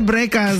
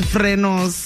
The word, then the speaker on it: frenos: The right way to say it, apparently, is frenos.